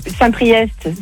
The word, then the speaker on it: Saint-Priest: T'as Saint-Priest, c'est EST, tu le prononces.